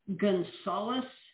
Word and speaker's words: Gunsaulis: Gunsaulis. – Gunsaulis. – Is really kind of how it's pronounced.